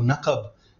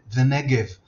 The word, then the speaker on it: Negev: Even the name of the territory itself, the Negev, is pronounced Nagab or Naqab among the Bedouin, and in Hebrew and English quite often the Negev.